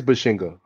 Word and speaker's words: Bazinga: Oh no, I did say it wrong. It is Bashinga.